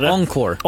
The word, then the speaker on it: Encore: Den här nya versionen då heter The Life of a Showgirl, The Encore, om jag uttalar det. – The Encore. – Encore, precis. Och jag har med mig in i studion som jag ändå får ge rollen som vår redaktions största Swiftie på något sätt.